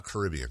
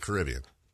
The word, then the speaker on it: Caribbean: It's not Caribbean Queen. – Yeah. – Caribbean. Only two times that's pronounced that way. Pirates of and Billy Ocean. Everyone else pronounces it Caribbean. Except for Billy Ocean and Disneyland.